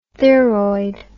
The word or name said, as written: theroid